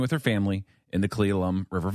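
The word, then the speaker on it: Cle Elum: Now, if you remember from yesterday, there was a 10-year-old girl from Federal Way who was lost at the Cleelum River.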